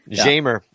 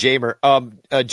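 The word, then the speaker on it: Jeimer: As you you might remember us mispronouncing his name as Jamer. – It's Jamer. John Pickett says,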